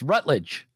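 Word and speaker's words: Routledge: It's Rutledge.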